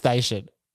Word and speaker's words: Steichen: I think it's Steichen.